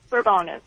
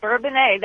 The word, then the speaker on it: Bourbonnais: It is not Bourbonus. – We all remember the 1976 Bourbonnais Police Department thing. So they named it that, huh? – It was Bourbonnais. That was how we were supposed to pronounce it.